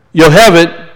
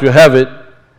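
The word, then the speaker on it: Yocheved: Then we have 3115, which is Yohevet, if we say it like it's pronounced, Yohevet.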